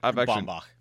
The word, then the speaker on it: Baumbach: Noah Baumbach, is that how you say it? – Baumbach. – Baumbach with the bomb.